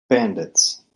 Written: bandits

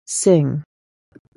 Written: Singh

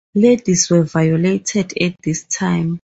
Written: violated

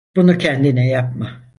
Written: kendine

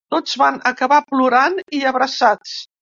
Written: plorant

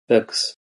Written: bugs

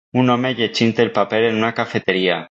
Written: llegint

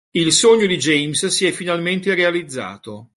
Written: finalmente

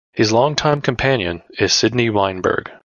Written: longtime

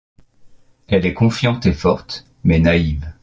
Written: confiante

